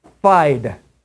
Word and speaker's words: fide: Now, actually, the word is, before we read the scripture, sola means alone, and an actual way that you pronounce the word is fide, F-I-D-E, fide.